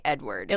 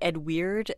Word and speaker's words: Eadweard: Right, and Edward, E-A-D-W-E-A-R-D, Edward. – It looks like it should be Ed Weird, and some people would say that he was.